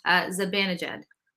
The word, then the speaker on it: Zibanejad: Zibanejad.